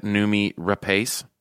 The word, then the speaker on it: Noomi Rapace: Noomi Rapace? – Yeah.